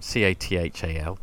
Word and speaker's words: Cathal: C A T H A L, Cathal.